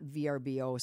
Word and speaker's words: Vrbo: And so there would be, I'm supposed to say Vrbo, not VRBO. Sorry to those of you who are listening and scold me when I say it wrong.